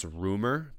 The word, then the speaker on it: RMR: His name, it's pronounced Rumor, but it's RMR. – Oh yeah, I know Rumor, bro.